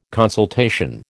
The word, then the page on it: consultation: American English